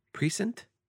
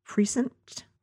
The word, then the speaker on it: precinct: Precinct? Mm. I always thought it was precinct. – Precinct? – That's weird. – Listen, words. I'm from Queens. – Yeah. – Nobody pronounces the N in that.